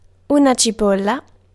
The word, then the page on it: oignon: France; [ɔ.ɲɔ̃]